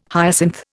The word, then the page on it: hyacinth: Indian